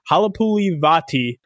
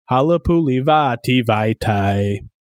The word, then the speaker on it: Halapulivaitai: I got a little tip from Michael Kist from Bleeding Green Nation to pronounce it like Play That Funky Music, White Boy. It's Halapulivaitai. Nope, I screwed it up already. Halapulivaitai. Halapulivaitai. That's how you pronounce it, chat.